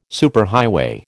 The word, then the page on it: superhighway: American English